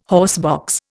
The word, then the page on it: horsebox: Indian